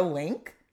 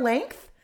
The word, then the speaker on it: length: How have I lived 35 years hearing people say car link? And they're actually saying car length. And you know what? I don't think that's on me, dude. I think people need to know to pronounce a T and an H at the end of a word.